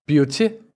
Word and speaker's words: Beauty Pacific: Men det udtales. – Beauty. – Beauty. – Beauty. – Pacific. – Pacific. Okay, så jeg havde... Og det er ja, det var 80% procent rigtigt. Og det er faktisk dansk.